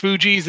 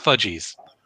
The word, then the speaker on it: Fugees: Oh, and but I was just to confirm that that hip hop group from episode 2, that's pronounced Fugees and not Fudgies. Just so we're clear. – Well, there's no D, so it's not fudge.